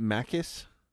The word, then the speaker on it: Maccus: would be Davy Jones and Maccus. – Is it Maccus? – I think so. It's M-A-C-C-U-S, so I'm assuming the C's are hard C's.